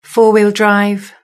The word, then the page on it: four wheel drive: British English